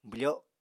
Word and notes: bwfo: apatani

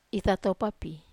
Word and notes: tatopa: Wamesa

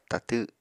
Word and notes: tatw: apatani

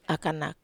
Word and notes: akanak: Wamesa

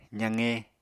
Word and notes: zaqe: apatani

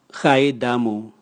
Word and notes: gaidamu: Raga

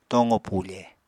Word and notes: tooqo pufe: Apatani

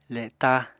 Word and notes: le7ta: apatani

